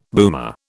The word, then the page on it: boomer: British English